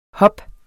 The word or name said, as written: hop